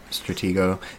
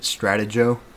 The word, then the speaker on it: Stratego: Stratego. If you pronounce it Stratego, go home.